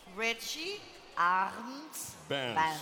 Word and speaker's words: Richie Arndt Band: Para ouvir, e agora vamos tentar pronunciar isto como deve ser. Richie Arndt Band.